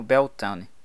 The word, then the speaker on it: Beletaine: Em que é exatamente a 2ª lua cheia após Beletaine, e Beletaine se pronuncia como Beltane. Então, por que você está me olhando assim?